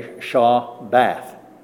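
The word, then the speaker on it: Shabbath (שבת): We would pronounce it that way, Shabbath, with the emphasis on bath.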